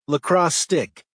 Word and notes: lacrosse stick: American English

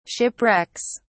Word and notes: shipwrecks: British English

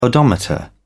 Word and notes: odometer: British English

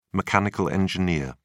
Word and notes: mechanical engineer: British English

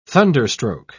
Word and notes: thunderstroke: British English

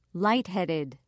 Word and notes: lightheaded: British English

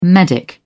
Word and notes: medic: British English

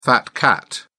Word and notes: fat cat: British English

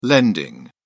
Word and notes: lending: British English

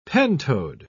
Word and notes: pentode: British English

